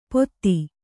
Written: ಪೋಥಿ

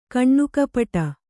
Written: ಕಣ್ಣುಕಪ್ಪಡಿ

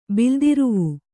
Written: ಬಿಲ್ದಿರುವು

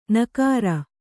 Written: ನಗರ

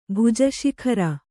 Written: ಭುಜಶಿಖರ

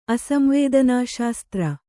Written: ಅಸಂವೇದನಾಶಾಸ್ತ್ರ

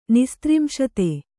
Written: ನಿಸ್ತ್ರಿಂಶತೆ

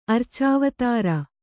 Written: ಅರ್ಚಾವತಾರ

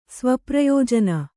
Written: ಸ್ವಪ್ರಯೋಜನ

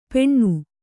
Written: ಪೆಣ್ಣು

